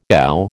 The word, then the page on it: ow: American English